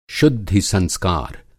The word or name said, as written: शुद्धि-संस्कार